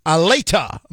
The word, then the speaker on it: Allata: He's the founder of Aleta.